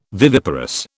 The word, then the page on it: viviparous: British English